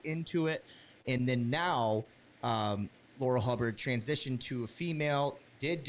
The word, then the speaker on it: Laurel Hubbard: Laurel Hubbard, I believe we got the name right this time around.